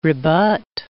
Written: rebut

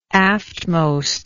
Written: aftmost

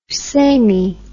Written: samey

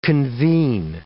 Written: convene

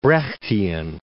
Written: Brechtian

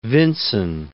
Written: Vinson

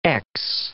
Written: x

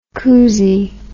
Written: couzie

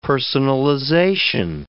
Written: personalization

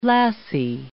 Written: lassie

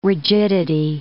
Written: rigidity

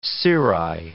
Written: cirri

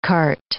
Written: kart

